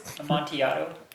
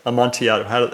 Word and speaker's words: Amontillado: Amontillado. – Oh, is that how you say it? Amontillado.